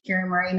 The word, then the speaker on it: Kiri-Maree: Kiri-Maree, not Kiri-Maree Moore. – That's what I tripped up on. There was like an extra M and an extra little syllable there.